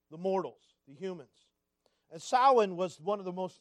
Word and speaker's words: Samhain: And chief among these festivals was what they called the Fire Festival, or Samhain. All right, it's spelled actually S-A-M-H-A-I-N.